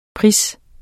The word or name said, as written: pris